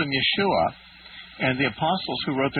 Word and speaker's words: Yeshua: And that Hebrew pronunciation, Yeshua, is transliterated in the Greek New Testament as Jesus. – So Jesus. – And so it's Yeshua is the Hebrew and Jesus is the Greek.